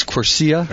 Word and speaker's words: Quercia: I've asked him, how do you actually say it? I want to say it right. He says it's Italian. I believe it's Quercia.